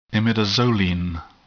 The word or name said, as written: imidazoline